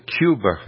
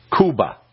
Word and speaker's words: Cuba: We called it Cuba, and they said, no, Cuba. That's how it's pronounced.